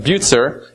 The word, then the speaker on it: Bucer: The last name is pronounced a little differently than you would pronounce it in English, but Bucer is the correct pronunciation.